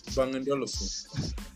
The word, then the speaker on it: Bang & Olufsen: Bang & Olufsen.